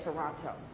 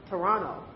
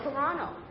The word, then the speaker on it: Toronto: And if you're not from Toronto, they know that because you still say Toronto. It's pronounced Toronto. That's how they say it. Now, I figured something like Psalm, where the P is missing. Well, the T is missing in Toronto. So it's Toronto.